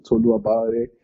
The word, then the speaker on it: Tolu Obalade: So my social media is literally my name, Tolu Abare.